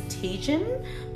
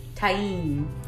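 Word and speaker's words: Tajín: The English way I would pronounce it is Tajin, but in Spanish they say Tain.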